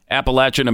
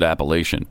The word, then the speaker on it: Appalachian: Appalachian American. I used to pronounce it Appalachian, but I think everybody calls it Appalachian.